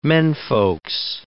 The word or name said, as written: menfolks